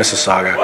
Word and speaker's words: Mississauga: It's actually Mississauga.